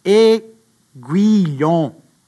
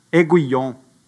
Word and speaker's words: aiguillon: Aiguillon. Aiguillon.